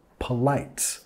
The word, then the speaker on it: polite: Nota acá que no decimos enfatizando las dos sílabas, polite más p, p. La primera sílaba igual en como police, ¿no? La policía. P, p, polite.